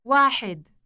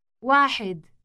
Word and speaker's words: واحد: واحد. واحد.